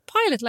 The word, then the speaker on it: Pilot: No, it was like Pilot. Pilot, like the flyer. Pilot. P-I-L-O-T.